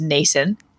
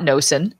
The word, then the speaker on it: Nason: It is Nason, not Nosen. for anybody. I've heard it mispronounced.